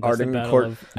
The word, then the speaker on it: Agincourt: Agincourt.